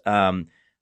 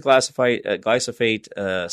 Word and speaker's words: glyphosate: Glyphosate, I think I'm saying that wrong. Glyphosate. There we go.